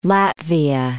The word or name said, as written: Latvia